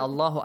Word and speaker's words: Allah: Whenever Fatha or Dhamma comes before Allah, you have to pronounce it heavily.